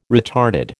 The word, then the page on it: retarded: American English